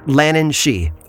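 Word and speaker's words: Lhiannan Shee: It's spelled differently but pronounced the same, Lannan she, although this creature is far more dangerous than her Irish counterpart.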